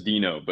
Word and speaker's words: Deno: So I pronounce it as Dino, but that is a very I think hotly contested topic in within the community.